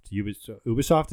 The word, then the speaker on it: Ubisoft: Ubisoft, is that how you say it? – Yep.